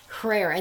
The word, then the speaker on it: hrair: It's spelled H-R-A-I-R, Herrera, and that means Herrera.